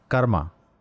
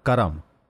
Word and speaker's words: karma: The word karma, which is pronounced karma or karam, is often thought simply as what goes around comes around, a universal debt of sort that needs to be paid or rebalanced.